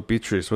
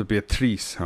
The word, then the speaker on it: Beatrice: OK，这是 D flat major seven，然后另外一个就是刚刚听到是 Sam Rivers 的这个 Beatrice 啊，那你说 Beatrice 或者 Beatrice 啊都可以，看看你用哪一个文发音这样哈。